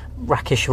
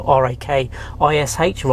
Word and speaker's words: Rakish: Uh, my handle is the Clear Coach, so whether it's on Instagram, LinkedIn as well, Rakish Rana. Uh, thankfully, there was a spelling mistake at birth, so my name is spelled R A K I S H rather than the usual E.